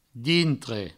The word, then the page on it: dintre: Provençal